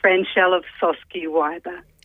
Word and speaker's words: Franchelle Ofsoské-Wyber: It's Franchelle Ofsoské-Wyber.